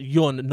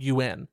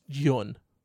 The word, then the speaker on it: Yeun: Apparently, uh, his last name is spelled is you say it Yun, not UN, even though it's like Y E U N or Y U E N. It's Yun.